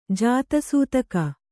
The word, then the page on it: ಜಾತಿಸೂತಕ: jāti sūtaka